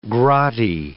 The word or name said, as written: grotty